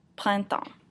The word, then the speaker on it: printemps: So to say spring, you would say le printemps, printemps. This is a hard word because it has two nasal vowels in it, so the first syllable is print, and the second syllable is temps.